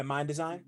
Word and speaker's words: Mind Design: And then moving down further into the track list, a random thought that came to me in hearing the Mind Sign feature, if I'm saying his name correctly. – Yeah, Mind Design. Yeah, Mind Design. Okay.